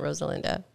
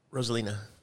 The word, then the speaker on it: Rosalina: He would be the one I'd use if it wasn't Rosalinda. Rosalina.